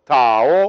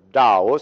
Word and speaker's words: Tao: Taoism says in the beginning there was the One called the Tao or the Dao, sometimes pronounced.